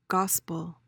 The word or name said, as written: gospel